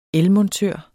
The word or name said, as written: elmontør